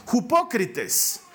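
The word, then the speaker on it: hipócrites (ὑποκριτής): La palabra hipocresía viene de una palabra griega que se pronuncia hipócrites y que literalmente hipócrites significa ponerte una máscara ser un actor, interpretar un papel.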